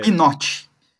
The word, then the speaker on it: Pinot: Pinote. – No, vi... é, no vídeo da. É que nem o, o vinho Pinot Noir, né? Que se escreve, é o P-I-N-O-T.